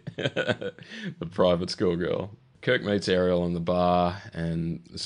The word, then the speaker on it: Areel: It's Ariel, but it's spelled... – The Little Mermaid? – It's spelled A-R-E-E-L.